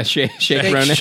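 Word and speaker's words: Saoirse Ronan: Sarsi Ronan. – Sarsi Ronan. – No, shake. – Yeah, shake Ronan.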